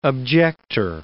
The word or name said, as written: objector